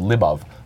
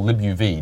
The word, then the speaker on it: libuv: But it sits on top of something called libuv, or libuv, depending on how you pronounce it, which is a cross-platform evented I.O.